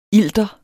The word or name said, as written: ilter